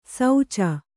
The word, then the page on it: ಸೌಚ: sauca